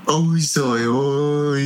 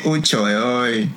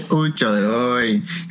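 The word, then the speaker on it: Trời ơi: So I understood the Zoi Oi part of it, but that's the northern pronunciation. – Yeah, right? – That's the northern pronunciation of it. In Saigon, we say Choi Oi.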